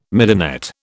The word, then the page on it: midinette: British English